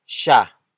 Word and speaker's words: siya: The next word is siya. Siya. Siya. Siya is spelled as S-I-Y-A.